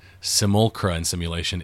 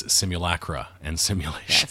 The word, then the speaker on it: Simulacra and Simulation: It is, the name of the book is not, as we were saying, it is not Simulacra and Simulation. It is Simulacra and Simulation.